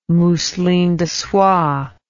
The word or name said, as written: mousseline de soie